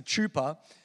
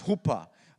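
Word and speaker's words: chuppah: It looks like it's a chupa, but it's actually pronounced hoopah.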